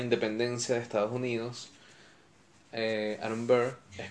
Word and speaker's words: Aaron Burr: En la segunda canción, él conoce a Aaron Burr, o Aaron Burr, o no sé, yo le digo Aaron Burr porque así es como se pronuncia.